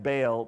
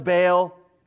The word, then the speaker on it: Baal: We have a big confrontation between the prophets of, it's spelled B-A-A-L. It sounds like Baal, but it's pronounced more like Baal. But I don't want to try to say Baal throughout the night, so we're just going to call it Baal, okay?